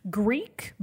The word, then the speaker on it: Greak: Greek, but it's spelled G-R-E-A-K.